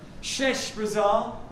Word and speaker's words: Sheshbazzar: A guy called Sheshbazzar, and congratulations to Kath for her pronunciation, Sheshbazzar leads the first group of returnees, and then a guy called Zerubbabel comes to help.